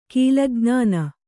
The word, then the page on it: ಕೀಲಜ್ಞಾನ: kīlaj'nāna